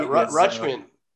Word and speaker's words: Rutschman: Adley Rutschman, if I'm pronouncing that correctly. – Yeah, Rutschman.